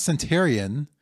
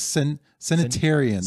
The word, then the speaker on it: centenarian: It's not centurion, I don't think. – Apparently, they throw an extra EN in there. – There's an extra syllable. So it's a centenarian.